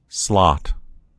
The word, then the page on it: slot: American English